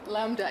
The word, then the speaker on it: lambda: Does he say lambda? Lambda. Lambda, yeah, I don't want to pronounce it wrong if be made fun of.